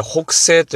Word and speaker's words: 北星: えー、千葉県はですね、阿鼻子市というところに北西神社というお社がございます。字はですね、えー、東西南北、北ですね。北。そして星、月とか星のね、北星と書いて北西と読むんですね。